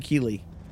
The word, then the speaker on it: Kylee: It's actually Keeley. It's spelled K-Y-L-E-E, which is kind of hilarious, and I kind of love it.